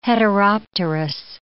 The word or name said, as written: heteropterous